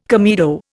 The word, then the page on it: comedo: Indian